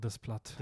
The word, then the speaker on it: Desplat: Alexandre Desplat.